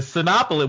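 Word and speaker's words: Cinépolis: It's French. It's pronounced Cinepolis.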